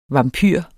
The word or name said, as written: vampyr